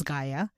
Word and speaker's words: Gaia: So the goddess of the earth, her name was Gaia. And I'm pronouncing it right.